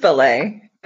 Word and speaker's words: Bellet: No, it's just Bellet, but everyone gets it wrong. It's fine. – Okay. – Yeah. Just Frenchify it a little and you're good.